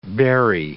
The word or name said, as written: berry